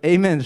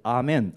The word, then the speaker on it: Amen: Amen a women 是叫 women 还是 woman 我不知道是单数还是复数 Amen a women 这个 Amen 是他们美国人的发音，正常来说标准的发音是 Amen， Amen 是一个希伯来话，就是原文就叫 Amen，它就是真实的意思。